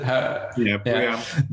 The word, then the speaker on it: BREEAM: BREEAM, is that how you pronounce it? – BREEAM.